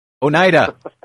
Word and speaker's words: Oneida: Brad, I was really gobsmacked when I heard you mispronounce the word Oneida. – Oh, Oneida. Thank you for letting me know.